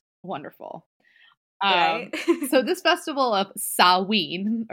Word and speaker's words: Samhain: So it's called the festival, and it literally looks like it's pronounced Samhain, but it's actually pronounced Samhain.